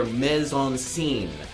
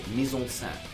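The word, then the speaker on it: mise-en-scène: John, you stated hair implies the entire ensemble of hair, the entire mise-en-scene. The correct pronunciation is mise-en-scene.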